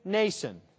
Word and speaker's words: Mnason: And they arrive at the house of a man named Mnason. Yes, the M there is silent, like in mnemonic device.